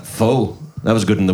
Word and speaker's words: Pho: Pho. Of course, I was told that it's pronounced fa.